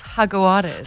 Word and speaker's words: Jaguares: It's Jaguares.